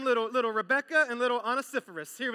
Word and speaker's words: Onesiphorus: It is Onesiphorus.